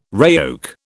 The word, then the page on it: RAOC: British English